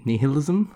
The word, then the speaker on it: nihilism: Ni- nihilism? How do you say it? – Nihilism. Nihilism. – Nihilism.